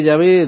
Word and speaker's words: Yahvé: Parece que Yahová es un tipo más reciente de pronunciación de la época, tal vez del siglo XVI, y que Yahvé, de hecho, es la correcta pronunciación del nombre de Dios.